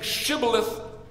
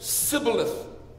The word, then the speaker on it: shibboleth: The Ephraimites lisped when they pronounced the Hebrew letter shin. And so instead of saying shibboleth, they said sibboleth.